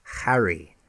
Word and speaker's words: Harry: It's Harry.